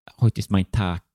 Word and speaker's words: Heute ist mein Tag: Uh, eh, Heute ist mein Tag är det väl? Ja, den är för nice. rätt uttal.